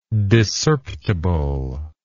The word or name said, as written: discerptible